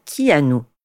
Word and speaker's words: Keanu: Pause, parce que oui, Kino, ça se prononce en réalité qui nous